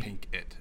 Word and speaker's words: Pinkett: Jada Pinkett Smith. – Yeah, Pinkett. Is it P-I-N-K? – E-T-T. – Pink It. Okay, yeah.